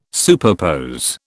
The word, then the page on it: superpose: British English